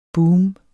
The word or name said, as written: BOOMETS